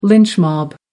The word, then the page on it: lynch mob: American English